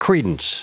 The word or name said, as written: credence